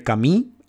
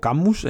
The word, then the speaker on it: Camus: Em, el verano 1953 es de Albert Camus o Camus, en, eh, si obviamos la pronunciación francesa.